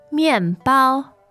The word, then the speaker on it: 面包: 面包。面包。 – Great. – Fourth and first tone.